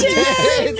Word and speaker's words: Thames: The second one's silent. – Yes, the Thames. It is the Thames.